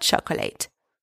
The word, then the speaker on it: chocolat: Hot chocolate. Le chocolat chaud. Don't pronounce the T at the end of chocolat.